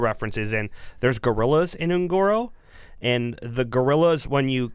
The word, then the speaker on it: Un'Goro: Like most of Hearthstone's expansions, is it Un'Goro? Is a reference. – Un'Goro, yep. – Is it Un'Goro? – Un'Goro. You gotta say it like an orc.